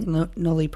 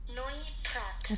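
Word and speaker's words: Noilly Prat: Noy Prat, French. – Noy? – Noy Prat. – I think it's Noy. – Noy, you're right. – Noy Prat. Noy. Okay. – Noy Prat, French.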